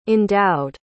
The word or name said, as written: endowed